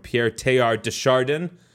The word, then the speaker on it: Pierre Teilhard de Chardin: As we focus on the presence of God, here's a great statement about that from Pierre Teilhard de Chardin.